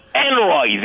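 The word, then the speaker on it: Henry: And, you know, but it doesn't work, because Peter Noon always said, I'm Enroy the Eighth. He added a syllable.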